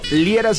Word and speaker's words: Liera: A mí en todas las redes sociales por mi nombre, Fernando Liera, se escribe L-I-E-R-A.